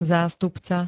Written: zástupca

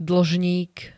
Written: dlžník

